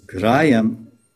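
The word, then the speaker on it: grāhyam: And he says, the Bhagavatam, Bhaktya Bhagavatam Grayam. It says, Grayam, G-R-A-H, long A, Y-A-M, Grayam.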